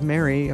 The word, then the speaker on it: Mary: The name is spelled Mary, of course, M-A-R-Y, and M-A-A-S, two A's, one S.